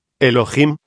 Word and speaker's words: Elohim: Diosas y dioses, eso significa Elohim, o mejor dijéramos, Elohim, porque la H se pronuncia como J.